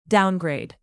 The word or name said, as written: downgrade